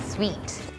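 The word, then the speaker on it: suite: sweet sweet room sweet เนี่ยมันจะออกเสียงเหมือน sweet ที่แปลว่าหวานเลยค่ะ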